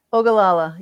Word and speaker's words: Ogallala: I mean, I think that the you're gonna have to teach me how to pronounce this, because I always screw it up. But the Ogallala, is that right? – Ogallala, yeah.